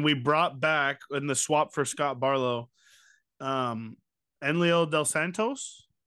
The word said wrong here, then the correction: Enyel: Enlio De Los Santos. – Enyel. There you go. That's how you say it.